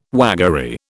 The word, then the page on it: waggery: British English